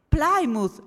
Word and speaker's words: Plymouth: Si scrive Plymouth e si legge? Plymouth. Ah, oh, grazie. Ok, allora finalmente lo posso pronunciare giusto.